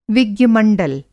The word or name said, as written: विज्ञ-मंडल